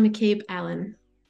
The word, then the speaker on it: McCabe Allan: It's Morgana McCabe Allan.